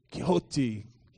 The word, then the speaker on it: Quixote: But there's a story called, there's a guy called Don, is it Don Quixote? How do you say it? Quixote, yeah. Yeah, Don Quixote.